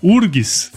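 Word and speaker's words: UFRGS: a famosa URGS. Falei certo, né? né, Ricardo? Sim.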